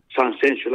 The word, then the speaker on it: Sanxenxo: Entonces, yo creo que está bien dicho de las dos maneras, pero lógicamente a nosotros nos gusta más que se diga Sanxenxo. La primera con S y después las dos siguientes con X, ¿no?